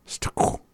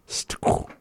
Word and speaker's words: stqw: Stuko. We're gonna find out. But anyways, stuk is mud. Stuk. And that is sa ta qua. S-T-Q-W. Stuk. Sa ta qua. S-T-Q-W.